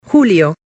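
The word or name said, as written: julio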